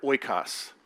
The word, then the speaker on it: oikos: It's this Greek word oikos, and that's how I pronounce it.